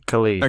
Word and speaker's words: Khalid: Ian loves the song Numb by Khalid, however you say his name. – Khalid. – Okay. Yeah.